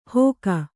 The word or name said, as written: ಹೋಕ